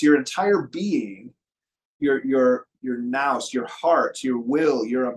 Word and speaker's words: nous: So the ancients would often talk about, and you read this in Orthodox theology, about the word N-O-U-S, like you can pronounce it nous.